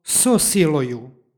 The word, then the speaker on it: so siloju: Tu imamo ono pravilo o dodavanju izgovornog o kada predlog i sljedeća reč počinju istim glasom. Između njih se dodaje izgovorno o radi lakšeg izgovora i preciznijeg prenošenja značenja.